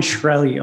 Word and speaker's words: Shkreli: Uh, I don't know how to pronounce his last name, like Martin Shrekley or oh, something. Yeah. Martin Shkreli. Oh my gosh. – Yeah. Shkreli